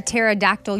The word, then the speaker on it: pterodactyl: Why can't you hear a pterodactyl use the bathroom? – Why can't you hear a pterodactyl use the bathroom? – Because their P is silent.